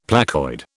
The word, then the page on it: placoid: British English